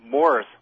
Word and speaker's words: Maurice: Actually, it seems that Morris was the actual pronunciation of his name, not Maurice. – Well, how do you know that? When you say it seems, I mean, seems based on what? – Well, I mean, from what I've heard, and every time I hear his name pronounced, by the Gibbs themselves, it's Morris.